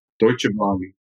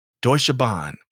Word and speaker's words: Deutsche Bahn: Deutsche Bahn. – Deutsche Bahn.